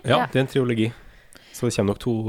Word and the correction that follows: trilogi: Ja, du bare eh, uttalte den O-en som ikke er der. Prøvde bare å arrestere deg på det på en utrolig Triologi? Trilogi.